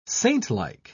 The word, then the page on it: saintlike: British English